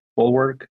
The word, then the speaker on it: bulwark: That impact is not only good for humanity, but will in turn increase visibility for the ecosystem and provide a bulwark, sorry, pronounce that word, bulwark, against public criticism. – Bulwark, yeah.